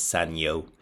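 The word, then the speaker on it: Sanyo: Then we've got Carlos Daniel Gutierrez, or Senyo, or Senjo, what I've also heard, which sort of makes me wonder on how he actually pronounces his nickname. But as I've heard from Spanish-speaking people, it's Senyo.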